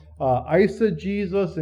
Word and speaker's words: eisegesis: It's called eisegesis and exegesis. That's how you pronounce those.